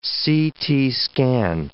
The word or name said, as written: CT scan